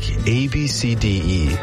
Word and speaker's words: ABCDE: The one that had people doing a double take, ABCDE. Yep, spelled ABCDE, but pronounced Ab-C-D-E.